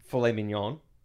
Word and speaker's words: filet mignon: And I went to say filet mignon. Is that how you say it? – Yeah.